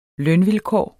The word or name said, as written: lønvilkår